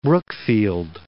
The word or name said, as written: Brookfield